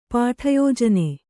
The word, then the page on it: ಪಾಠಯೋಜನೆ: Kannada